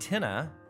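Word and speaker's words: Tine: Then there's one called Tinna. It's T-I-N-E, but it's pronounced Tinna, Irish for fire.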